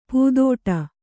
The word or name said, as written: ಪೂದೋಟ